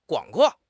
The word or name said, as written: 广阔